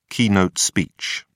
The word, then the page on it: keynote speech: British English